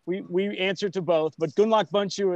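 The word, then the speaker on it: Gundlach Bundschu: Well, if you have a long time and the elevator ride is long enough, you say Gundlach Bunshu Winery.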